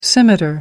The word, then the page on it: scimitar: American English